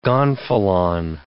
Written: gonfalon

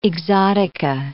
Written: exotica

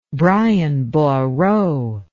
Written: Brian Boru

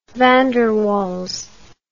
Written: van der waals

